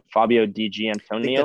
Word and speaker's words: Fabio Di Giantonio: I'm going to butcher his name so bad, but Fabio Di Giantonio. – That's right. – Is that how you say it? That's right.